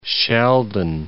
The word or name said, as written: Sheldon